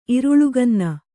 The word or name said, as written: ಇರುಳುಗನ್ನ